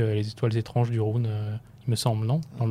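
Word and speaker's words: Rhûn: Euh, et le, j'avais noté aussi euh, l'inconnu Enori qui part, euh, qui vers le, alors on dit Rune, Rune. Rune. C'est tu vraiment jusqu'au au bout de la prononciation. – Il faut rouler les R.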